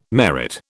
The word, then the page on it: merit: British English